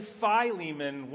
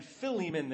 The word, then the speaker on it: Philemon: If I say Philemon 1 minute and Philemon the next, I'm talking about the same person.